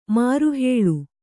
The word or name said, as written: ಮಾರುಹೇಳು